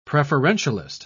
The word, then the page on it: preferentialist: British English